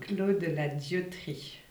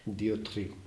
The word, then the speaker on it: Clos de la Dioterie: How do you pronounce that? – Clos de la Dioterie. – Dioterie. – Yeah, you do it well.